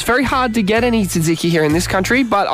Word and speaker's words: tzatziki: It's called tzatziki, right? – Tzatziki, right? – Okay. – TZ, right? – Silent T.